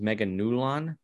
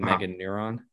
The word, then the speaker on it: Meganulon: I just want to mention, or I believe the pronunciation is Meganulon with an L rather than Meganuron.